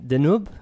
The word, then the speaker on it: Deneuve: I wanted to say about Catherine Deneuve.